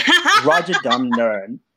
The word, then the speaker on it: Rajadamnern: It's called Rajan. Damn it! It's called Rajadamnern. Rajadam Nern Stadium.